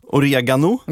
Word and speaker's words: oregano: Oregano. – Ja. – Det är rätt korrekt uttalet. Men det är rätta uttalet. – Rätta uttalet, ja.